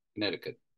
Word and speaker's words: Connecticut: Yeah, no, Brian Justman on Mixler said you don't pronounce the second C. Leaf Kay said that you had them both right first time. – Connecticut.